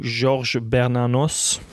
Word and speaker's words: Georges Bernanos: If you would like to go to that game, it is at 33 Avenue George Baranos. Do not know if I said that 100% correctly. – 33 Avenue Georges Bernanos. – There we go. That is much better than my pronunciation.